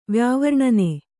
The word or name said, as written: ವ್ಯಾವರ್ಣನೆ